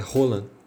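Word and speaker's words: Holan: A pronúncia é Roland. Ariel Rolon.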